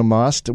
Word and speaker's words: Maust: And we're welcoming first time guest in studio with us today, Mona Maust with DNS Landscaping here in San Antonio.